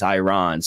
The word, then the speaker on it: Iran: So I was a political major, political science major in college, and everyone pronounced it Iran in college, like all the students. But our political science professors, he always corrected us and said it is Iran.